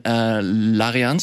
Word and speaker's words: Larian: Jetzt kommt Baldur's Gate von Larian Studios, ist das richtig ausgesprochen? Ja.